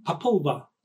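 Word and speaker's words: Popol Vuh: So now we go to the Papulva, I think is pronounced correctly, Papulva. Angelica's nodding her head, so yes, that's how you pronounce it.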